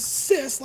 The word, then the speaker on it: cis: It's pronounced as cis, like S-I-S, as in sister.